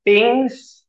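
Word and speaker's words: PNG: Pings, okay, it's supposed to be pronounced as ping. That's what the developers wanted.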